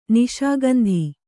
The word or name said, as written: ನಿಶಾಗಂಧಿ